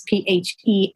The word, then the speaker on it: Phelan: His name is John, it looks like Phelan, but I'm pronouncing it wrong. It's Phelan.